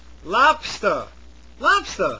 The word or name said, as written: lobster